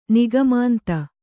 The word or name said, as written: ನಿಗಮಾಂತ